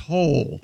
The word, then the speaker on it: Hole: Detective Ole. – Yeah. – Ole. – But it's pronounced Hole. – Yeah. – Ole? – Detective Hole. Yeah. – It's spelled H-O-L-E.